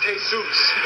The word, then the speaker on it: Jesus: Yeah, Indian Joe Roach and a Mexican fella named Jesus Alvarez. – Hey, Bob, that's pronounced Jesus. – Well, they spell it the same way Jesus spelled his name. – I know, but you don't say it that way.